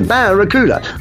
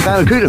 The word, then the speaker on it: Barracuda: Hey man, the name of the new Plymouth fastback is the Barracuda. I can't pronounce Barracuda. Well listen man, try this. Say ba, ba, ra, ra, coo, coo, da, da.